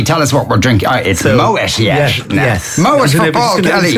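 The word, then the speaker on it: Moët: If anyone's wondering about the pronunciation, it's because there's a two little dots over the E, which means it's you pronounce both syllables of the word, so it's Moet. Moet.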